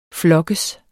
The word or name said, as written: flokkes